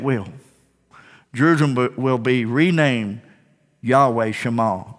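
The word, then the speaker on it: Yahweh Shammah: To pronounce that is Yahweh Shema.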